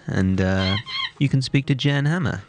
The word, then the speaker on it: Jan Hammer: Jan Hammer. – It's a hard J. – So it's not Dutch? – It's not Dutch.